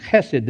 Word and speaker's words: chesed: You may see it spelled H-E-S-E-D or C-H-E-S-E-D, which is designed to be pronounced chesed, right?